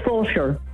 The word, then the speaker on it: Fulshear: How do you say the name of the town? – It's Fulshear.